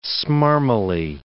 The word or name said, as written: smarmily